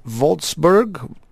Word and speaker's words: Wolfsburg: For those of you just joining us, Thomas grew up in Wolfsburg. Did I say that right? – You do great.